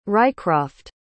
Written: rycroft

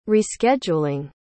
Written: rescheduling

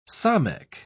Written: samekh

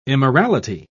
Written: immorality

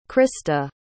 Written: Krysta

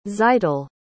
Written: tzeitel

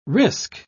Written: risk

